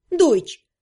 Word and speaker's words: Deutsch: Вроде как в немецком, где стоящие рядом «е» и «у» читаются как «ой». А «че» вообще пишется четырьмя буквами? Кстати, в самом названии языка «Deutsch» наличествуют два примера.